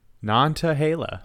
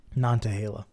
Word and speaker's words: Nantahala: There's a T. – Nantahala. – Oh, Nantahala. I thought you said Nantahala. – Yeah, I mean, that's how you pronounce it. – Nantahala.